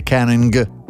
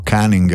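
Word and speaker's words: caning: In certi casi estremi esistono ancora le cosiddette canning o canning, a seconda di come la vuoi pronunciare, cioè punizione con la canna di rattan.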